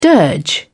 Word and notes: dirge: British English

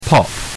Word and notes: pop: British English

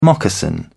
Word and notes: moccasin: British English

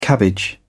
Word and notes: cabbage: British English

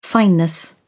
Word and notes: fineness: British English

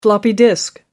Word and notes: floppy disk: British English

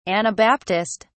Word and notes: anabaptist: British English

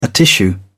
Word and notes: atishoo: British English

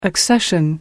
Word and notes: accession: American English

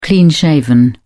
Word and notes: clean-shaven: British English

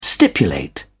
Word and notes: stipulate: British English

